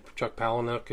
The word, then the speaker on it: Palahniuk: Or whatever you pronounce his name, Chuck Palahniuk, who wrote Fight Club.